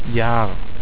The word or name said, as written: jahr